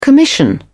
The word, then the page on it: commission: British English